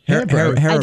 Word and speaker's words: Haribo: I mean Haribo.